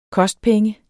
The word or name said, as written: kostpenge